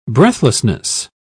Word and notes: breathlessness: British English

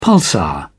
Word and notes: pulsar: British English

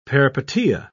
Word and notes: peripeteia: British English

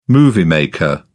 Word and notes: moviemaker: British English

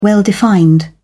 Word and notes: well-defined: British English

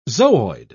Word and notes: zooid: British English

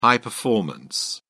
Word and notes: high-performance: British English

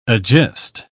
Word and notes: agist: British English

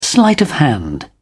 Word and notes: sleight of hand: British English

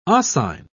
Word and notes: oscine: British English

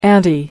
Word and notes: auntie: American English

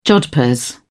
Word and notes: jodhpurs: British English

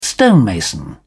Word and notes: stonemason: British English

